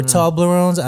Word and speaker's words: Toblerones: But the one thing though that was really stupid popular were Toblerones. You remember those? The little triangle? – Yes, things? or Toblerones. I don't know how you pronounce it. I'm sorry, whoever. – Yeah, you got it. – Toblerones.